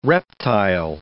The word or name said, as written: reptile